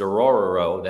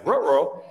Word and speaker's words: Orhorhoro: Uh, Ruke Orhorhoro, and I know that sounds like Scooby Doo, but it's it's Orhorhoro. That that is his name. That's how you pronounce it. I looked it up. – Yeah, Orhorhoro.